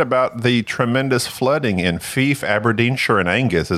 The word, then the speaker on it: Fife: It's pronounced Fife. – Fife. – Life. You don't ask thief.